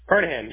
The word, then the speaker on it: Carnahan: Did I pronounce it correctly? – Carnahan. Yep, that's it. – Okay. All right. And then you spell your first name, C-O-N-N-E-R? – O-R. C-O-N-N-O-R.